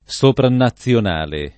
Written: sopranazionale

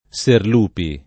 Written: Serlupi

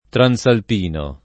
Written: transalpino

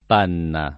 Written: Panna

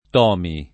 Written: Tomi

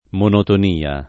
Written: monotonia

